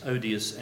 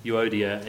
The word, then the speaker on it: Euodia: I can remember years ago one of our senior female leaders in the church I was converted in used to refer to them as odious and so touchy. But I think it's actually pronounced Euodia and Syntyche, but they were obviously having some difference and Paul wanted them to actually work better together.